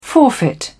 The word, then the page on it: forfeit: British English